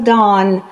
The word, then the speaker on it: Down: And yes, I spell Dawn with D-O-W-N.